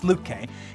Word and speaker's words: Lütke: I always get his name wrong, but it's Lütke.